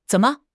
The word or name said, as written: zen3 me5